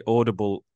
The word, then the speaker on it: audible: By the way, Audible is three syllables. Just. Audible. I couldn't resist it.